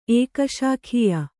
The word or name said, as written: ಏಕಶಾಖೀಯ